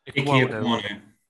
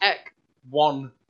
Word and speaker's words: Ekwonu: Ikwonu. – Yep, that part. And that's what we go. that's what I'm taking. – Just break it down into its syllables, though. It's just Ikwonu. Like Ikwonu.